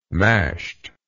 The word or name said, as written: mashed